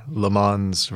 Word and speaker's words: Le Mans: So the 24-hour Le Mans race, am I saying that correctly? – Well enough.